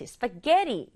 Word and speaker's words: spaghetti: 발음은 스파게티 게에 강조해 볼게요. We're having spaghetti. 그렇지, spaghetti 이렇게 발음해요.